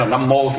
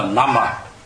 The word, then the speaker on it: Nam Mô: Chứ đúng là một người biết chữ Tàu không thì người ta sẽ nói Nam Vô, bởi vì chữ đó là chữ Vô chứ không phải chữ Mô, phải không? Nhưng mà mình đọc nó quen là Nam Mô thì mình cứ đọc là Nam Mô. Đó là phiên âm thôi. Nhưng mà đúng là ngày xưa cái phiên âm đúng của nó là Nam Ma.